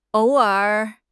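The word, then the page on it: ou3 er3: tones 3, 3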